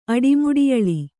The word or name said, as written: ಅಡಿಮುಡಿಯಳಿ